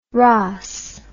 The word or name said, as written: raas